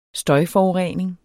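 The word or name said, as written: støjforurening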